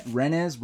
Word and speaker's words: Rennes: Rennes. I know in French, it's like you don't pronounce the S, so it's like René.